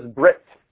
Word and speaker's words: brit: The other word is brit. Brit. B-R-I-T. In Ashkenazic, they pronounce it bris, B-R-I-S, but in modern Hebrew, Sephardic Hebrew, it's brit, B-R-I-T.